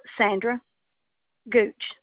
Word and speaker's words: Sandra Gooch: Sandra Gooch, G-O-O-C-H. – And that name is spelled S-A-N-D-R-A-G-O-O-C-H? – Yes, ma'am.